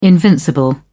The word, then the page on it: invincible: British English